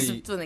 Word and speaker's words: 습도: 근데 습도 느낌. – 근데 이게 비읍이 가끔 어려울 때가 있어요. 받침으로 습도. 있으면. 왜냐면 그렇죠. 이게 습이 멈춰야 되잖아요. 숨을. 습도. 이게.